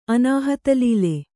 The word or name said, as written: ಅನಾಹತಲೀಲೆ